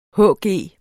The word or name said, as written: hg